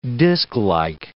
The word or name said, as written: disklike